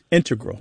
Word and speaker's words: integral: Yeah, but both correct, just to clarify here, both correct, but the mathematicians and math-using scientists are more likely to integral with the stress on the first syllable.